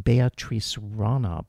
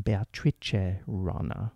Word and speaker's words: Beatrice Rana: You know, before I pronounced this pianist's name, Beatrice Rana, but I really have to use the Italian pronunciation, Beatrice Rana.